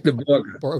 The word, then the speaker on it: Borge: Victor Borge, I think that's how you say his name. – Victor Borge.